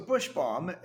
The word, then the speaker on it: Bush Balm: Okay, so Bush Balm, and we'll spell that for you in case you don't know how to spell it. B-U-S-H-B-A-L-M, silent L in the word balm.